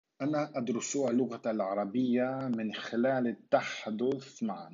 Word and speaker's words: اللغة: So uh, if there is no pause before the hamza of al, we don't pronounce that hamza. So we say اللغة, but we say أدرس اللغة.